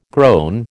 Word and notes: grown: American English

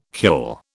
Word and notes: cule: British English

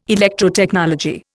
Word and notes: Electrotechnology: Indian

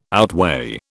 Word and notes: outweigh: British English

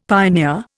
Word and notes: pioneer: Indian

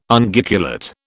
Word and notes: Unguiculate: British English

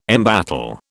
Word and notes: embattle: British English

